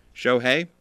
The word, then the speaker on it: Shohei: It's the only one that rhymes with Shohei. Shohei, Blue Jay.